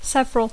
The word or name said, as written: several